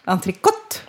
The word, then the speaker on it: entrecôte: Sen har vi då entrecôte. Hur uttalar ni det? – Entrecôte? – Ja, entrecôte ska det faktiskt uttalas. – Det ska vara T på slutet där.